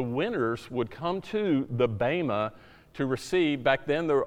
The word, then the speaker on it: bema: It's spelled B-E-M-A, but pronounced bema.